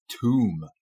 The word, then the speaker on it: tomb: Yeah, it looks like it should rhyme with like the word bomb or something, right? But it's pronounced very differently. Yeah, and it's tomb, pronounced tomb. – Tomb, yeah. So the B is silent.